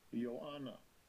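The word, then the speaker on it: Ioanna (Ἰωάννα): Strong's G 2489, Ioanna.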